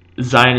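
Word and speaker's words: Zayin: Zion is obviously pronounced a Z, a regular Z sound, and it is possible that with certain without a dagesh, it was pronounced as a zh sound, as a ZH sound, if you will.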